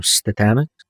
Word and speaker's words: Statamic: I remember an interview, Ian, you had with Jack McDade from Statamic. – Oh, yeah. Mm-hmm. – Is my pronunciation Statamic right? – Yep.